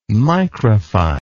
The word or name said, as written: micrify